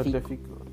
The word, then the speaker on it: Tagliafico: Uh, Tagliafico.